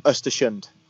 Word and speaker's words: Östersund: Östersund.